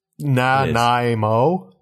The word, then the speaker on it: Nanaimo: That's spelled the way it sounds. – Yeah. – Is it though? – Nanaimo?